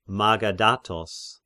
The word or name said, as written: Magaddatos